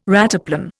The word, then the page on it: rataplan: Indian